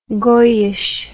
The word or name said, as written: goyische